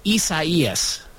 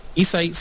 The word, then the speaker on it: Isaias: It's spelled almost like it's spelled similar to Isaiah, but it's pronounced Isaias.